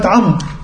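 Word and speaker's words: عمرو: كلمة عمر. وإحنا لما نكتب عمر النطق إيه؟ عين مفتوحة ميم راء. إيه دخل الواو؟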